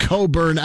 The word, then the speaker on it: Cockburn: How do they get Coburn out of that?" – Oh, yeah, that's true. That's pretty good. And if you saw his spelling, phonetically speaking. – Yeah, I know. – His name is Cockburn.